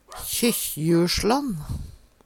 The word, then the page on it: KjykkjjuLslann: Numedalsmål